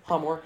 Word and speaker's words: Hamor: Yeah, Hamor, yeah.